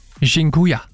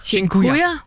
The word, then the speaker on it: Dziękuję: Og en af dem, som jeg har brugt rigtig meget, det er det, der hedder, og undskyld min udtale, Dziękuję. – Dziękuję? – Dziękuję.